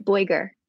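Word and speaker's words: Boiger: Alexandra, is it, how do you say her last name? – Boyger. – Oh, well, that's what I would have said.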